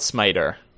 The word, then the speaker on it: SMIJT: SMIJT pronounced smite, as in the Lord will smite thee.